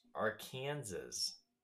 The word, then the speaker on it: Arkansas: So, fun fact, this may ruffle your furry feathers, but in Kansas, there is a river with the same spelling as Arkansas, and it is pronounced Arkansas.